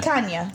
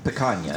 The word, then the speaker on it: picanha: called picanha. – Yep. – And it's a... – Is that the proper pronunciation? – I don't know how to pronounce it. – Our grammar expert. – Yeah. Picanha.